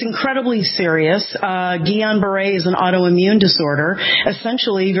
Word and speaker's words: Guillain-Barré: This diagnosis is called Guillain-Barré, I believe that I'm saying that right. Am I saying that right, Dr. Ruby? And how serious is this? – You're saying it right, Sue, and I want to thank you for staying on this.